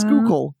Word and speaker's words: Schuylkill: It's like when you taught me how to say Schuylkill, which is not what it says. But you were like, it's Schuylkill. It's the Scholial Kill or something.